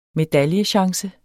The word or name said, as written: medaljechance